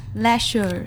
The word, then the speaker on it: leisure: And then and the next words we have laser. – Oh, no. – Laser. – Leisure. – Yeah. Okay, so the right word to pronounce leisure, mm-hmm.